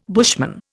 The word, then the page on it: bushman: Indian